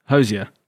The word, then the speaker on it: Hozier: Hozier.